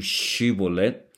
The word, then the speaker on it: Shibolet: El Efrateo no podía pronunciar la SH, la SH, sino que decía, en vez de Shibolet, decía Sibolet.